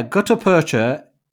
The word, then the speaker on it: gutta percha: Now, gutta percha.